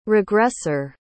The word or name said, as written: regressor